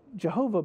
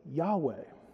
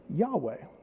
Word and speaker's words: Yahweh: Well, it turns out, since, that scholars have discovered that the actual ancient Hebrew pronunciation for the mysterious tetragrammaton, which was the name of God, isn't Jehovah, but Yahweh would be a more correct way that we would say that, Yahweh.